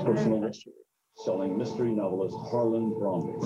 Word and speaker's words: Thrombey: Sounds right to me, based on what Thrombey. I see. Thrombey. It's Thrombey. That's awesome. Cool. Sorry. – Trombley. – It's not Trombley.